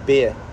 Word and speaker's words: Bare: B-A-R-E. – Oh, how do you pronounce it? – Beer.